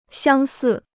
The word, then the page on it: 相似: xiāng sì